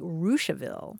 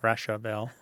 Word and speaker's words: Russiaville: a town near where I grew up in north central Indiana is spelled Russiaville, but it's actually pronounced like Rushaville. – Oh, interesting. – Yeah. And apparently the founder, or it was named for a guy whose last name was Richardville. – So the French pronunciation corrupted into the English mouths. – Yeah. But it looks like Rushaville. – Rushaville.